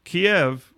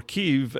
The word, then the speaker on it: Kyiv: Kiev, or Kyiv, as apparently the Ukrainian pronunciation is,